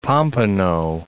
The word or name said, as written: Pompano Beach